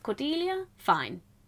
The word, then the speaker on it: Cordelia Fine: Cordelia? Fine. – Cordelia, didn't watch that one. Cordelia? Finn. – It wasn't the pronunciation, it was Finnia. Is that actually her name? – Her name's Cordelia Fine. She's nice.